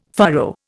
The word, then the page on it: furrow: Indian